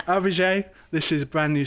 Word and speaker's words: AVIJÉ: And the first time we saw her name written down, we did actually ask her for a pronunciation guide. And she sent me a little voice note saying it's AVIJÉ.